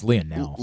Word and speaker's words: Lionel: I believe it's Lionel. – Lionel Messi, the French pronunciation.